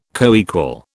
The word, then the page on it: coequal: British English